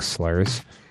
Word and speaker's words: Slyrs: This one just says it's pronounced S-L-Y-R-S. Is that just like slurs?